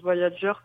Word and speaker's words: Voyager: moi, je le dis dans ma tête, je le prononce Voyager comme le Moog, qui s'appelle, donc qui est un synthé qui porte le même nom.